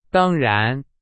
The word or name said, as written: 当然